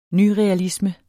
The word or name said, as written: nyrealisme